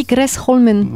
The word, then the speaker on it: Gresholmen: En norvégien, alors c'est marrant parce que ça se dit Gresholmen. – Voilà. – Alors si je le prononce bien aussi, à peu près. Ils ont ce truc, il y a un mélange un peu de sonorité anglaise, mais ils roulent un peu les R.